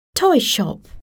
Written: toy shop

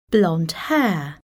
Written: blonde hair

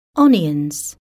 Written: onions

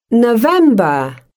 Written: November

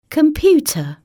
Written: computer

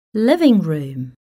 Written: living room

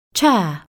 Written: chair

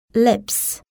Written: lips

